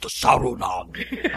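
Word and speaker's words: Saruman: But, but as Christopher Lee would say, it is pronounced Sarunog.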